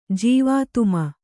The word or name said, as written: ಜೀವಾತುಮ